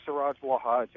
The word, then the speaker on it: Siraj Wahaj: And this is Siraj Wahaj. Am I pronouncing the name correctly? – Yes, you are. Siraj Wahaj out of Brooklyn, New York.